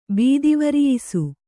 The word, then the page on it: ಬೀದಿವರಿಯಿಸು: Kannada